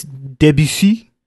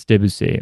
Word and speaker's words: Debussy: It's Debussy. – Oh, it's Debussy.